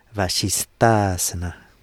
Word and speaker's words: Vasisthasana: The first S in Vasisthasana is the same sound as in seer. Vasishta is a seer between the sight and the seen. Vasishta is a seer between the sight and the seen. Vasisthasana.